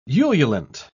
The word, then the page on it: ululant: British English